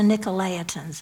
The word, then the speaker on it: Nicolaitans: Here's the Nicolaitans.